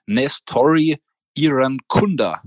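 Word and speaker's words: Nestory Irankunda: Äh, Bayern will den Australier Nestori Iran Kunda, spreche ich ihn mal aus. True or not true? – Das ist true. True. Und True ist auch, du hast ihn sehr schön ausgesprochen.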